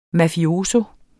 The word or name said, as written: mafioso